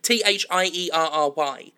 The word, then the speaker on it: Thierry: And also, I've got to actually give a shout out to Theory. I don't know how to pronounce that name. I only ever see the name written down. Is it Thierry? Theory? Like T H I E R R Y.